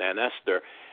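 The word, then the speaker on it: Aunt Ester: You pronounced it Aunt Esther, and that, if you say it quickly, is ancestor. Esther is spelled E-S-T-E-R, no H, and that's deliberate.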